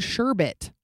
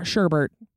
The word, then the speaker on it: sherbet: The word sherbet, not sherbert. That's really upsetting.